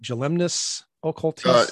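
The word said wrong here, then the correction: Jilemnický okultista: Yelimniki Occultista. – Yelimniki Occultista.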